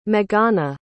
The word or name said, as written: meghana